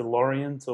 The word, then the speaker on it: Lorient: Is it Lorient or? – Yeah, Lorient.